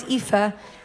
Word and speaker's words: Aoife: It's Aoife, A-O-I-F-E, the normal way.